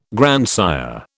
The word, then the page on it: grandsire: British English